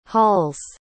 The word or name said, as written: hauls